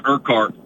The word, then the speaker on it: Urquhart: But it's Urquhart, but yeah, you know, like I said, I'm used to it.